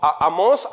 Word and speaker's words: Amós: Ese Es el libro de Amos Lleva acento en la O. habló, Amós habló en el reino del norte.